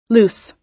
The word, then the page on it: loose: American English